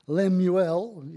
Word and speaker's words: Lemuel: It's in two parts, and so it's it's the words of King Lemuel is how it's pronounced, which is a, uh, is meaning devotion to God.